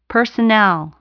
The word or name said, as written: personnel